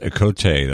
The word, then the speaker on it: Ocote: I want to tell you that Okote, that's how I'm going to pronounce it, O-C-O-T-E, Soul Sounds, and that is Adrian Quesada and another friend, I think he's from the Black Pumas.